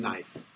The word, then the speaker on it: knife: Why is there a K in knife? – Oh. – I don't know, but just remember, there is a K in knife. Do you know the answer why there's a K in knife? Go ahead. The K and the N together, exactly like the word. What's the most common word that has the K and the N together where you don't pronounce the? – No.